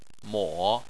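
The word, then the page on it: mo: tone 3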